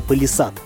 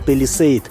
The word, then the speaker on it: Palisade: Сложно не вспомнить о песне про Вологду в исполнении песнеров, когда речь заходит о новом флагмане марки Hyundai под названием «Палисад». Вообще-то название этой машины правильно было бы произносить Palisade, но вы же понимаете, в России этот полноразмерный кроссовер на английский манер никто величать не будет.